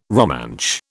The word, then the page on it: Romansh: British English